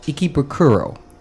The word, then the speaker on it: Ikebukuro: And then showed another district in Tokyo called Ikebukuro.